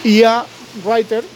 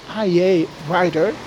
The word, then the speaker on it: iA Writer: Y IA Writer, bueno, lo he dicho mal, ¿no? Sería IA Writer, que es una aplicación de escritura de creativa, de esta de focus, de enfocarse en la escritura, pues tiene esa compatibilidad.